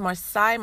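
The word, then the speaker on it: Marsai: It's pronounced Marseille Martin.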